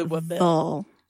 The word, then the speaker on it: Louisville: You have to say Louisville. Like Nashville. – Louisville. – No, there's no ville. It's a vol. Louisville. – Louisville. – Nashville. – So you say it without opening your mouth. Louisville. – It rhymes with full.